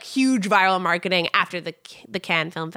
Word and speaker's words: Cannes: Cannes. – Cannes, yes.